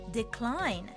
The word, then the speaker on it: decline: Decline Decline Sounds great。 C と L がくっつく時の発音を意識してみましょう。